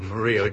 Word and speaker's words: Murillo: I, by the way, just as an aside, I appreciate your correct pronunciation, which I did not make, of Murillo. Judge Sirica would be very proud of the fact that you pronounced it correctly and ashamed of me.